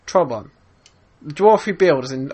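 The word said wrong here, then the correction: Torbjörn: Oh, Torbjorn? – Torbjorn.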